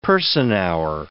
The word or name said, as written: person-hour